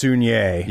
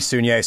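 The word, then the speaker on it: Saulnier: Jeremy, it turns out his last name is pronounced Sunier. – Yes, my apologies. I've been mine as well. – All of us have been. So. Jeremy Sunier.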